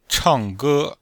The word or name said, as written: chang4ge1